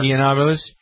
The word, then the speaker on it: Yiannopoulos: Yiannopoulos.